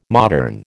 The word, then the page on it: modern: American English